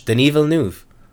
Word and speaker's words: Denis Villeneuve: It's legit. That's how you pronounce it. It's French. Denis Villeneuve? – Okay.